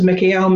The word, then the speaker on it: Mikael: And then we're going to add Michael's name in there, but we're going to be using a closer pronunciation to the Hebrew, which is Mikael, Mikael, Mikael.